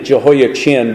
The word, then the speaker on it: Jehoiachin: Let me just stop there and note that I'm pronouncing it Jehoiachin because that helps to distinguish between Jehoiakim, who reigned in his place, but really it's a letter there that is pronounced as a hard CH. But it's hard for us to distinguish just by the M and the N at the end of the word.